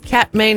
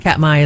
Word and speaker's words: Katmai: Fat Bear Week returns to Alaska's Katmai National Park. I said that wrong. It's Katmai, isn't it? – Right.